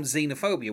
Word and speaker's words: xenophobia: Um, apart from xenophobia, which is pronounced with the with like a, obviously with the the Z sound, that's the only track that didn't start with like an E sound.